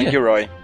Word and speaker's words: Dan Gilroy: Do, dos três roteiristas que meteram a mão, eu achei legal porque tem o menino Dan Gilroy, é assim que pronuncia? – Dan Gilroy.